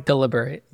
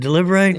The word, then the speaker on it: deliberate: So they go and they liberate. Is that, did I say it right? – Deliberate. – Is it deliberate? – Yeah, deliberate. Not liver. Not liberate.